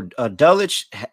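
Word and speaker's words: Dulcich: How do you pronounce his name? Dulwich. Yeah, so Dulwich, it seems like he is practicing and he could potentially return to this game.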